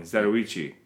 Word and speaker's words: Zatoichi: Is that how you say it? – Yeah, Zatoichi. – I've always said it wrong. Zatoichi.